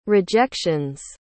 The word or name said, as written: rejection's